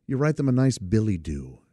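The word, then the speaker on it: billet-doux: Now, it's spelled billet, B-I-L-L-E-T, hyphen, or dash, D-O-U-X. So it looks like billet-doo-X, but it's not. It's Billy-Doo, and it means a love letter.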